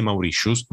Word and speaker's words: Mauritius: Però vorrei, in Europa vorrei ricordarti, vorrei ricordarti che non si dice le Mauritius, ma si dice Mauritius, no, Mauritius perché è una ragione.